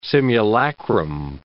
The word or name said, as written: simulacrum